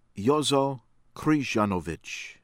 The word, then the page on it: ANDABAK, IVAN: EE- vahn AHN- dah-bahk